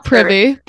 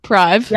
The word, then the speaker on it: Prive: And thank you for pronouncing it correctly. – Yes, not privy, Prive. – Yeah, not privy.